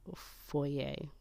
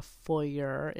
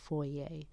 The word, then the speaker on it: foyer: Just, you know, it's the word is, you know, Latin origin, but it's a French word. And it's foyer. It's not a foyer. It's spelt that way, but it's pronounced foyer. And that's honoring the French where it came from.